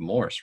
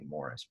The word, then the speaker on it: Morse: I think what stood out to me in that one is that Matt Lewis and Vado Morse, I think it's Morse, right? Every time in the broadcast, I swear they say Morris.